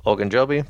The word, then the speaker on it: Ogunjobi: Ogunjobi.